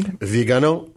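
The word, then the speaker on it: Viganò: Did I pronounce it correctly? – Vigano. – Vigano. – Right, got it. – Who accent on the last syllable.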